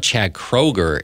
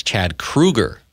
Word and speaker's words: Chad Kroeger: And now the latest thing is that apparently we've been saying his name wrong. It's not Chad Kroeger. It's Chad Kroeger.